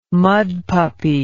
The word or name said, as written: mudpuppy